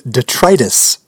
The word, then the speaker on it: detritus: How would you say that? – I think you're looking for the word detritus.